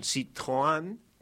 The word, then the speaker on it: Citroën: Surge de una información propia de la policía que obtuvo de que Camboya estaría circulando en un Citroën, porque me enseñaron que así se pronuncia en Citroën. – Francés. – Citroën. – Citroën. No es Citroën.